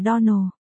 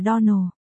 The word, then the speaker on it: Donald: Clarissa Almira nói với tôi rằng cô phát âm tên chúng tôi là Donald. Này cô Shirley, cách phát âm đúng của tên của chúng tôi là Donald. Nhấn giọng vào âm tiết cuối cùng, tôi hy vọng cô sẽ nhớ kỹ điều này từ nay về sau.